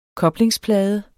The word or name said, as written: koblingsplade